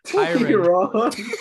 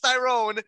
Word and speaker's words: Tyron: Tyron. – Yeah, I know. I just wanted to say it like that. I know it's Tyrone, but I'm not. I just want to say like Tyrone.